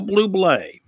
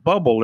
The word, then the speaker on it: Bublé: And Michael Bublé. Everybody says, his name is, it's written, it looks like it's Bubble or something, but no, it's pronounced Bublé.